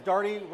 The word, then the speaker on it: Darty: We have Shannon Moore, who is the principal consultant at Darty Business Solutions, because Darty rhymes with party, and Anthony Algoman, who's the Convergence Platform Lead at AbbVie and author of the Data Leadership book.